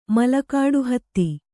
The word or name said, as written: ಮಲಕಾಡುಹತ್ತಿ